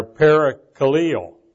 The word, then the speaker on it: parakaleo: Now, in the Greek, the primary words for exhort or exhortation are parakaleo. That's spelled P-A-R-A-K-A-L-E-O and paraklesis, P-A-R-A-K-L-E-S-I-S.